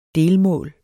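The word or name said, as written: DELMÅL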